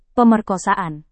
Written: pemerkosaan

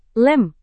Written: lem

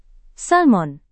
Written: salmon

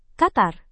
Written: qatar

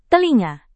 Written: telinga

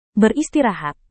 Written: beristirahat

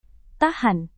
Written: tahan

